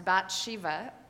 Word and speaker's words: Bathsheba: Her name is probably more correctly pronounced Bathsheba, but her bath defines her character in most of our minds.